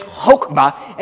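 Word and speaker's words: chokmah (חָכְמָה): And the word for wisdom in Hebrew is the word chokmah, okay? Chokmah. You got to get a little bit of feeling into it, but not really. But it's chokmah, and it's up there on the screen.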